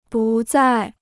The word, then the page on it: 不再: bù zài